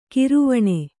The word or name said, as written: ಗೌರೀವೀಣೆ